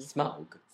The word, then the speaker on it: Smaug: Smaug.